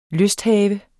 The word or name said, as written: lysthave